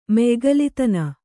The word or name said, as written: ಮಯಿಗಲಿತನ